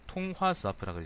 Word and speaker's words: 통화 스와프: 있 일본 아소 부총리의 통, 통화와프 망언 한국에도 돈 빌려주면 못 받을 수 있다. 통화 스와프가든요 내가 이게 발음을 통화 스와프라 그래야 됩니다. 통화 스와프.